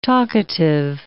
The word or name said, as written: talkative